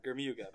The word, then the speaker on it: Germuga: It's a hard G. sort of rhymes with Bermuda is what I tell folks. Germuga.